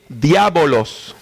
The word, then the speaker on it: diabolos: Lo pronuncié en griego, diabolos.